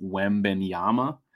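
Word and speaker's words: Wembanyama: I believe his last name is pronounced Wembenyama, but a lot of these guys are tanking for him.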